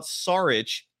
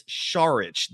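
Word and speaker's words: Šarić: But his last name is not Saric. It's Saric. The S is actually pronounced like an SH.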